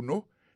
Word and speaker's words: know: Knowledge, c'est K-N-O-W-L-E-D-G-E. Knowledge, ça veut dire connaissance. Il sortit du verbe to know, qui veut dire savoir. To know, on a songé que c'est K-N-O-W. W. Alors ça que je ne vais pas prononcer K, c'est parce que K, il est silencieux.